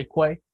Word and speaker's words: Kwei: And her name is spelled K-W-E-I, so I'm going to say Kwai.